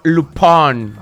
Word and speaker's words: Lupin: Es Lupin. – Ah, Lupin. – Lupin. – Estamos en Yucatán. – Se escribe, Lupin, pero ya vi que es Lupin.